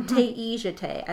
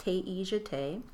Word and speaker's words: Taijite: No, Taijite. I looked up the pronunciation on our pattern page. It's pronounced Taijite.